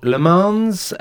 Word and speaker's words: Le Mans: Now, the new movie, Le Mans, is that the right pronunciation? 66. – Yeah.